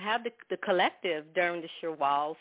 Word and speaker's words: Shawwal: But then, as you mentioned, the Shawwal, did I pronounce that right? – Yeah, Shawwal.